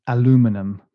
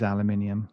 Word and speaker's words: aluminium: Yeah, which for our American friends is aluminum. – No. – And you're all wrong, by the way. It is aluminium.